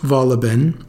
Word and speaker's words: Wohlleben: His last name is pronounced Wohlleben, but it's spelled Wohlleben.